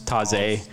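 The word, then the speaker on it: Tawse: From Taz or Taze. I'm not sure how you say it. – It's Canadian. It's Taze.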